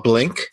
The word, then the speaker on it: Blynk: It's called Blink. That's spelled B-L-Y-N-K.